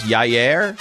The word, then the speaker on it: Yair: Y-A-I-R Rodriguez. – Well, how do you pronounce this? Yair? – I believe it's Yair Rodriguez.